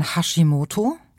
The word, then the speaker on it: Hashimoto: nämlich zum einen den sogenannten Hashimoto.